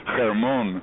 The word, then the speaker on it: Hermon: Hermon, it's really a Hebrew word from the Bible. In Hebrew, it's pronounced Hermon, Har Hermon, Mount Hermon in Hebrew.